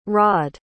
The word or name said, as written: raad